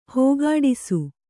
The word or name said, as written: ಹೋಗಾಡಿಸು